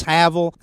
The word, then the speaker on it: hovel: H-O-V-E-L. – H-O-V-E-L. – You can pronounce hovel, you can pronounce havel. There's various pronunciations, but either way, it's a shanty.